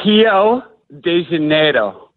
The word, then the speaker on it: Rio de Janeiro: It's Rio de Janeiro. So Rio, everything with an R there, like churrasco is how they say cookout, but it's actually churrasco.